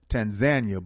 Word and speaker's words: Tanzania: One trip I'm definitely interested in, and I know you went last year, is it's pronounced Tanzania, but people pronounce it Tanzania, right? – Yes, it's Tanzania, and it can be pronounced Tanzania as we pronounce it in America, and pronounced another way.